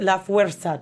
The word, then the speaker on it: La Fuerza: La Fuerza. Did I say that right? – Sí. – I did. Yes.